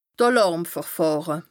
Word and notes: d' Àlàrmverfàhre: Bas Rhin

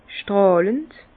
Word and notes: stràlhend: Bas-Rhin; Herrlisheim

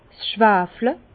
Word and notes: 's Schwafle: Bas-Rhin; Herrlisheim

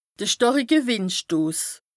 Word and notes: de stàrike Wìndstos: Bas-Rhin; Strasbourg

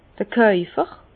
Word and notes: d'r Kaufer: Alsatian; Bas-Rhin; Herrlisheim

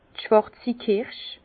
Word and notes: d' schwàrzi Kirsch: Bas Rhin; Herrlisheim

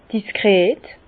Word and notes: diskret: Bas-Rhin; Herrlisheim

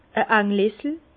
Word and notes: e Ànglessel: Bas-Rhin; Herrlisheim

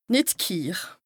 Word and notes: nìt ghiir: Bas-Rhin; Strasbourg

